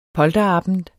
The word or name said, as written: polterabend